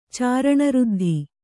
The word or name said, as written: ಚಾರಣಋದ್ಧಿ